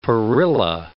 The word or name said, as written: perilla